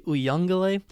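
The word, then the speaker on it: Uiagalelei: In fact, he missed the one loss this season, although you cannot put that loss on the quarterback game because DJ, I'm going to butcher his name up, but I believe the best way to say his name is DJ Uyunglele, that's what I've heard commentators say, had performed some fantastic football against Notre Dame.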